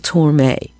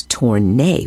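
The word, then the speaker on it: Tormé: The band opened in Flatbush, Brooklyn, on January 15th, 1942, and among the vocalists that were featured was a 17-year-old Mel Torme. If you listen closely at the top of the track, you'll notice that, in fact, the announcer mispronounced Mel's name as Torne with an N.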